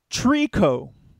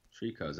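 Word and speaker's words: Treecko: Trico. – Trico, that's T-R-E-E-C-K-O. – C-K-O.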